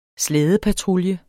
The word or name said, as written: slædepatrulje